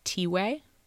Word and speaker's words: Tiwe: His name is pronounced T-way. So T-I-W-E.